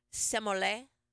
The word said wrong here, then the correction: sommelier: How do you say the sommelier? – Sommelier.